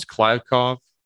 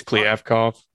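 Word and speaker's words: Kliavkoff: Since Pac-12 Commissioner George Kliavkov, whose name I think I butchered, I thought it was Kliavkov. – Yeah, apparently it's Kliavkov.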